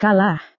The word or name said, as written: kalah